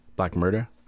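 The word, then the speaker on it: Black Merda: Black Murda? I'm not trying to put an accent on it. That's how it's spelled, M-E-R-D-A. It's supposed to be a play on words, I guess.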